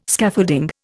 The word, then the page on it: Scaffolding: Indian